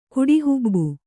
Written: ಕುಡಿಹುಬ್ಬು

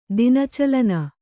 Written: ದಿನಚಲನ

